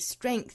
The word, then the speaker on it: strength: 首先我们做英音的语音讲解，那我们来看第一个要注意的词哈，就是 strength 这个词，我们早读经常出现，中间包含的这个字母 G 呢，对应到发音之中是一个清辅音的 k。